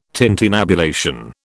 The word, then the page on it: tintinnabulation: British English